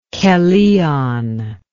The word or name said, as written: Kellion